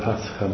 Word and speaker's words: Pascha: It's even embedded in their translation of what the word Pascha means, as in passage, rather than Passover.